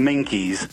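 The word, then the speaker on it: Minquiers: Right, and it's on these islands, which are even more southern than Jersey, called the, I think they're called like the Maniki Islands. – It's pronounced the Minkies.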